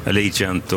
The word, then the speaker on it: Allegiant: Allegiant och Idao de Tiar. – Allegiant, var bra uttal.